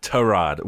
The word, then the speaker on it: Tyrod: Notice you're pronouncing it Tyrod, which is how he wants it, right? Isn't that the right way? – Exactly.